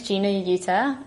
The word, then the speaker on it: Gina Yuter: So my name is Gina Yuter.